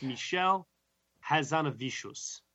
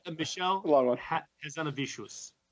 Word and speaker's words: Michel Hazanavicius: It's Michel Hazanavicius. I think I added an extra syllable in there by mistake because it's rolled off the tongue as such. But Michel Hazanavicius is the way you say it.